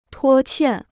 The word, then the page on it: 拖欠: tuō qiàn